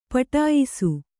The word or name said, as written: ಪಠಿಯಿಸು